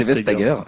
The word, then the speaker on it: Vestager: je pense que c'est Vestager.